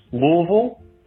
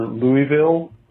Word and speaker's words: Louisville: Where you are in greater Louisville, you may hear it pronounced Louisville, which is more the common way of saying it, or Louisville, or Louis. Louisville is not that common. But you can almost tell where somebody lives in the city by how they pronounce it.